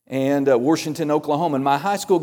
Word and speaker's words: Washington: And so we moved to this little podunk school south of Norman, Oklahoma called Washington yeah, you don't, you spell it Washington, but you don't say it like that. It's got an R in there.